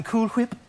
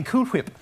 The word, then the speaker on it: Cool Whip: I'm saying Cool Whip. – Oh, you're saying Cool Whip. – Yes, I'm saying Cool Whip. – You're putting a lot of emphasis on the H.